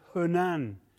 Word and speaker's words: Hunan: And it's in the Hunan is the correct pronunciation, region.